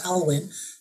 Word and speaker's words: Alwyn: And also the Alwyn, some people call it William Alwyn, but he's actually pronounced Alwyn,